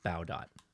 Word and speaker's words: Baudot: So I believe the person is Baudot or Baudot, but it's pronounced Baudot.